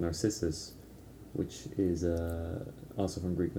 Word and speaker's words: Narcissus: Narcissus, yeah.